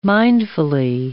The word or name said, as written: mindfully